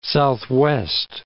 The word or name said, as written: southwest